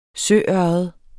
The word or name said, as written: søørred